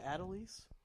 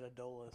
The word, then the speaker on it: Adolis: Is it Adelise? – It's pronounced Adelise. – I've always said Adolis. – I always thought Adolis, too. Yeah. But Baseball Reference says it's Adelise.